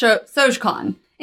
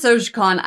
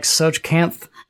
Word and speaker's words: Tsojcanth: So, Igwilv was first introduced in Gary Gygax's The Lost Caverns of Sojcon. And have some of my notes before them, so they might notice that it's spelled... T-S-O-J-C-A-N-T-H, and it's pronounced Sojcon.